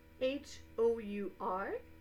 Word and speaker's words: hour: Hour, H O U R, means heure. It's a noun. The H is not pronounced, so hour is pronounced the same as our.